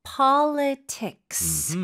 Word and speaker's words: politics: 네, politics. 끝까지 S도 발음해 주셔야 돼요. Politics. – Politics. 왜냐하면 S가 없으면 명사가 되지 않죠. – Yeah. – 그래서 S 포함이 돼야 되고 PO 쪽에 강세를 꽝 찍어주시고요.